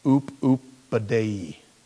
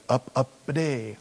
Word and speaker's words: Upupidae: Any of several old world birds of the family Oop-oop-a-day. Now, yeah, you know, I started looking at that word, oop-oop-a-day, and it's spelled U-P-U-P-I-D-A-E, and it's like up-up-a-day.